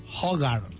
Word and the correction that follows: Hrothgar: It's like Hrothgar.